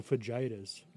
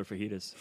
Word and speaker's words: fajitas: I call them fajitas. – No, they're fajitas. – Fajita.